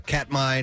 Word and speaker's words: Katmai: And this is out of Katmai National Park.